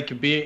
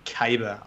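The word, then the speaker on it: Kabr: Deej mentioned on a live stream that the voice actor pronounced it wrong or something like that, and it should be Caber, I believe. I believe it should be Caber, not Kabir.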